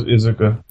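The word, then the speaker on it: Iizuka: I really have enjoyed Izuka, or Azuka, however you like to pronounce it. – I'm pretty sure it's Izuka.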